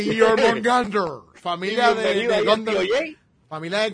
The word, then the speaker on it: Jormungandr: La J y la O con apóstrofe, your. – Se supone que sea your. Jormungandr. – Jormungandr.